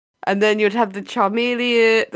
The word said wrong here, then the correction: chameleon: Charmeleon? I'm saying that right. – Chameleons. You mean the chameleons? – Chameleon.